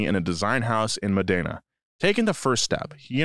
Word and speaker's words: Modena: Now, I don't want to get you in your head at all, but it's pronounced Modena. – Modena.